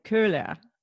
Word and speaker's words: Koehler: I'm Diane Kohler, as Germans would pronounce it. – And I'll try to say it again. Curler. Yeah. Almost like an Curler. E-R-H kind of a sound.